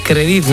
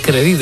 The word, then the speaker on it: Crédit: Bueno, te comentaba yo esa noticia de Cajamar y te decía Crédit, no es Crédit, es Crédit. Con acento en la E, Crédit Agrícola.